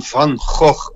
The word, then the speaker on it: van Gogh: Hola Rodrigo, soy Willem, Willem van Gogh, como se pronuncia en holandés.